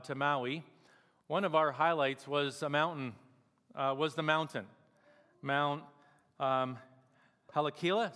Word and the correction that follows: Haleakala: is that how you say it? Haleakala. Sounds better.